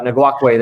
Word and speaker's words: Ngakoue: I'm not going to pronounce that. Ngakoue, there you go.